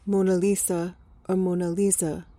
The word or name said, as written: mona lisa